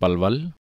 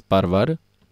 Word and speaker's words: Palwal: People in the region around Palwal pronounce Palwal as Parvar, Dalia as Darya, L is pronounced as R.